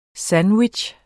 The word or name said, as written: SANDWICHS